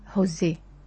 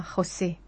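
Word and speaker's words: José: حيث يقول الكاتب: اسمي جوزيه، هكذا يكتب، ننطقه في الفلبين كما في الإنجليزية هوزي، وفي العربية يصبح كما في الإسبانية خوسي، وفي البرتغالية بالحروف ذاتها يكتب ولكنه ينطق جوزيه.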